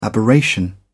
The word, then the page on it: aberration: British English